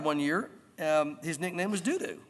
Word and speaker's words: Dudu: After him was Eleazar, the son of Dudu. Now, I'm not trying to be funny. I know that sounds bad. Dudu's how you pronounce it.